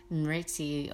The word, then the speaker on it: Smriti: It's spelled S-M-R-I-T-I, Smriti.